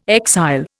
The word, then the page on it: exile: Indian